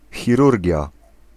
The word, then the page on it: surgery: English